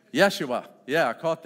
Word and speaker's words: Yeshua: There's a kind of a combination of Hebrew and Aramaic that would be pronounced Yeshua.